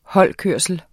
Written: holdkørsel

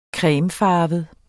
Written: cremefarvet

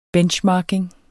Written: benchmarking

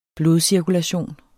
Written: blodcirkulation

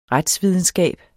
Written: retsvidenskab

